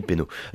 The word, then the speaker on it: Peynaud: Euh, qu'est-ce que je peux citer d'autre Ben l'influence aussi d'Émile Pénaud. Donc il faut absolument connaître hein, en tant que, dégustateur. Donc Pénaud, ça s'écrit P-E-Y-N-A-U-D.